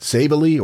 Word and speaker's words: Savely: Or Savely, S-A-V-E-L-Y. How would you say that? Savely or Savely? – Savely.